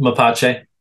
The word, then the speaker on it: Mapache: Your other group is called, as you mentioned a little earlier, Mapache. I think I'm pronouncing that right. – Mm-hmm. Mapache.